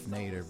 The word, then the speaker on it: Nader: Ralph Nader rhymes with neighbor.